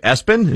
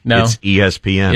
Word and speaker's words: ESPN: Do you say it Espen? – Yeah. – He frequently has to say, no. It's ESPN.